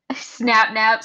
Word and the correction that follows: Sapnap: Snapchat. – Snapchat. – Snapnap. – Sapnap. Sapnap. Sapnap.